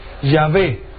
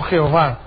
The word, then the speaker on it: Yahvé: Ellos mismos, en sus libros, enseñan que probablemente la mejor forma de pronunciar el nombre de Dios es Yahvé y no Jehová.